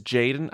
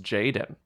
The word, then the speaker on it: JXDN: His name is Jaden. I think JXDN is. – Can I say Jaden? – It's just pronounced Jaden.